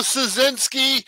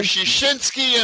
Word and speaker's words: Siecinski: Joe Siecinski or Siecinski in Polish or Siecinski. I don't care what you call me, just call me.